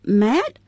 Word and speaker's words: mate: Mate, 他说成 mate? – 他把 M-A-T-E 读作 mate, 是他们的一种元音变化的结果。